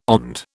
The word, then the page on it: OND: British English